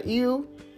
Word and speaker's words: ewe: So a ram is a male sheep, and then how you say it, Derek, a ewe, E-W-E, is a female sheep, and then a lamb is the child.